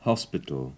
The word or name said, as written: hospital